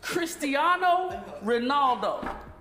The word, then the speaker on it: Cristiano Ronaldo: Cristiano Ronaldo. Is that, that's how you say it? – Pretty much, yes.